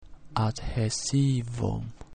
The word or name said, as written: adhaesivum